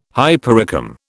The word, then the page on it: hypericum: British English